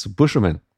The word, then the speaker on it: Beauchemin: I have my own mic, which is at South Tahoe Lake is Max Beauchemin. Did I pronounce it correctly? – Yeah, as good as it comes, except for people who actually speak French as their first language. But yeah, you did well.